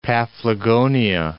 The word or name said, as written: Paphlagonia